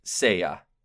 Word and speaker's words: Seiya: Seiya.